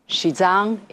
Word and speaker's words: Xizang: Our topic today, Xizang, which is better known abroad as Tibet. Xizang is the pronunciation of the Chinese name of the region.